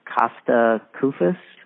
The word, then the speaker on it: Kosta Koufos: and Kosta Koufos.